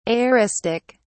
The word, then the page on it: aoristic: British English